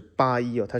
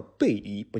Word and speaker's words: Bailly: 首先要肯定一点，就是他的确做过一些小研究，的确是这是一个呃其实挺常用的一个法语的姓，但是它读出来并不是八一啊，它是其实正确的中文其实。中文是有这个发音字的，所以说这里我不能给他满分，主要是这个原因。它如果是正确的翻译，应该叫贝伊，不叫八一，因为这个字读出来是贝耶啊，是叫贝伊，不是巴耶，是贝耶啊，所以说是一个。